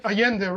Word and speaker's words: Allende: I forget that you don't pronounce the L's the way I just did in Spanish. – Oh, Allende, right.